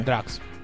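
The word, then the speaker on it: Drukqs: è la pronuncia giusta? – Sì, Drax.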